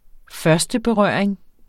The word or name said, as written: førsteberøring